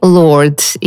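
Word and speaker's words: Lorde: Чтобы добавить феминности этому имени, она дописала в конце английскую букву «и». На произношение это никак не повлияло, так и появилась певица по имени Lord.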